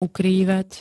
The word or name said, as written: ukrývať